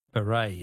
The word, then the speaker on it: beret: Words that end with a silent T. Beret is B-E-R-E-T.